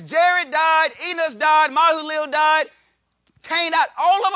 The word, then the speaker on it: Mahalalel: Verse number 17, Mahalalel, that's how you say it.